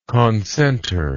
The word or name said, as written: concentre